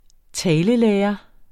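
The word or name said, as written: talelærer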